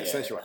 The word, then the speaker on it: Senua: Yeah, Senua.